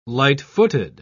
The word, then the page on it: light-footed: British English